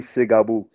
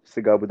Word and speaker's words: Tsgabu: people calling me Tsgabu. Tsgabu. That's easy to pronounce it, I guess.